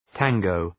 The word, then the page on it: tango: ‘tæŋəʋ